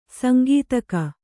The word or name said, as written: ಸಾಂಕೇತಿಕ